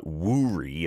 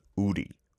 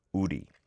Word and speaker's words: 우리: But when you see it romanized, like there's a bank that's called that, it's W-O-O-R-I, so it looks like wuri as well. But the correct pronunciation in Korean, uri, uri.